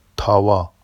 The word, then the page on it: tɑwɑ: Vafsi